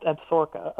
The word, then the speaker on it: Absaroka: What is it called? – It's pronounced Absaroka.